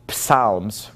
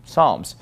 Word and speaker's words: Psalms: You know, we have a lot of words in English that start with P-S, but we usually don't pronounce the P, right? For example, the book of Psalms, we don't call it Psalms, we just call it Psalms. Psychologist, we would not pronounce the P.